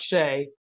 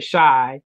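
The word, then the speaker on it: Shai: Okay, because I always thought Shea, And then I did see something where he said shy. And then I saw like a whole report of we've been saying it wrong this whole time.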